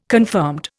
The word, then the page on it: confirmed: Indian